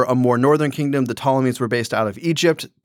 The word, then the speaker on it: Ptolemies: Judea, or Israel, is ruled over by the Ptolemies with a silent P. So it's not the Ptolemies, it's just the Ptolemies.